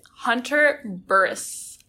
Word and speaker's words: Hunter Burress: Today I'm here with Hunter Burress.